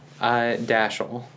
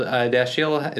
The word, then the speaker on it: Dashiell: Dashiell. – Dashiell. I was thinking that or Dashiell. Because the I. – Well, Dashiell is how it is traditionally pronounced. – Where's that from? – It's a French name.